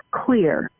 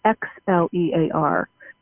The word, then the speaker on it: Xlear: So I do like a product called Clear. It's uh, X L E A R. Everybody pronounces it Xlear, but it's called Clear, and it is made by Spry.